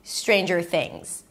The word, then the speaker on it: Stranger Things: The next syllable is jur, jur. You start with a j sound and you move it directly to the R, jur, jur. There is no vowel in between, stranger. And then the last word is things. Stick the tongue up for the TH, relaxed I, things, and end it with a Z because the S is pronounced as a Z. Things, Stranger Things.